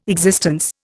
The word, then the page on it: existence: Indian